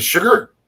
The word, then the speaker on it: Chigurh: Chigurh. – Is it Sugar? – Sugar's good.